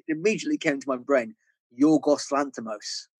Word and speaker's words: Yorgos Lanthimos: As a Greek man, Yorgos Lanthimos is a perfect pronunciation.